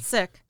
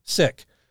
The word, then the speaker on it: Szyc: John Allen, I always butcher this name, Sizek, S-Z-Y-C. – Sick. – Sick.